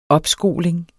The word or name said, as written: opskoling